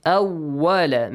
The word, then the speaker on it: awwala: Awwala. Make sure waw is not pronounced as V in English. Awwala, no.